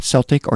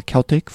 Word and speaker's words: Celtic Frost: Before that was Celtic or Celtic Frost, depending on your preferred pronunciation, Into the Crypts of Rays from the Morbid Tales record, Destruction, doing a song called Total Disaster.